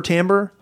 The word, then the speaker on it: Timbre: Timber Tambor, who would have thought that T-I-M-B-R-E was to be pronounced Tambor?